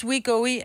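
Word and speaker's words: Wegovy: Ej, jeg synes, we go we er et når først man har hørt det på gang, så synes jeg faktisk... – Er det sådan, det udtales? – Ja. We go we.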